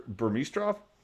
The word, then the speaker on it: Burmistrov: Okay, so Alexander Burmistrov? Am I saying that correctly? – No, you said it right.